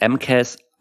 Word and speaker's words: MCAS: Also die Abkürzung ist MCAS, heißt das M-Case das oder spricht man jeden Buchstaben einzeln aus? – Nee, ist tatsächlich das MCAS.